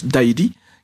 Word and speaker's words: dairi: Et en général, alors ce qu'a dit Nicolas me rappelait aussi quelque chose, c'est que la grande maison, il y a un mot sino-japonais qui se prononce « dairi »,